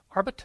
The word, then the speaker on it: Arbeit: Orbit. Orbit. Arbeit. How do you say that word? Arbeit. Yeah, it's Arbeit.